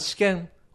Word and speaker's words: 私権: 2 番、私権。私の権利と書いて私権と読みます。